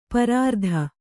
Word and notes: ಪರಾರ್ಧ: parārdha

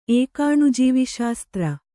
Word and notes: ಏಕಾಣುಜೀವಿಶಾಸ್ತ್ರ: Kannada

